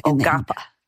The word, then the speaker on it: Quapaw: Ogapa.